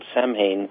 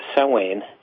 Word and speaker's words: Samhain: It's spelled Samhain, but it's pronounced Samhain.